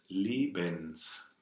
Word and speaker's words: Lebens: Des Lebens. Yeah, right. – And what I love about it is the schwa, the last syllable of that, the Lebens, is very, very close to the des, right? – Des Lebens, yeah.